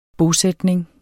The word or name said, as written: bosætning